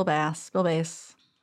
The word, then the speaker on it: Bass: Bill Bass. It's spelled like both. – Yep. – Dr. Bill. – B-A-S-S.